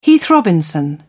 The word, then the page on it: heath robinson: British English